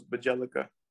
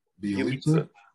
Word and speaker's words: Bjelica: My whole name I thought it was Bajelica, but it's Be. say it again. – Bjelica. Yeah. It's a crazy pronunciation.